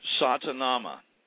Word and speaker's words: Satanama: There's Satanama, and there's actually a town geographically in India called Satana. You can actually look it up. Satanama is four syllables. Sa-ta-na-ma.